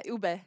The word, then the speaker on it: ube: The ube crinkles. Is that? Am I saying yeah. that? – Yeah, ube. – I, have never even heard of that. – Yeah, ube.